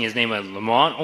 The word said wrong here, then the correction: Laurent: or whatever, between Lamont and the Laurent. Just say Laurent.